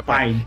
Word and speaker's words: Pine: O, o Pine, é Pine, né? Falei certo, né? Chris Pine, né? É, o pai, o pai, Pine, Pine.